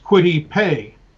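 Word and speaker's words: Kwity Paye: And his first name is K-W-I-T-Y. Last name is P-A-Y-E. I don't want to have to think about it. So it's pronounced Quitty Pay.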